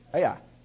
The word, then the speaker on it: Ai: It's Ai. Ai. That's how you spell it. That's how you say it. Ai.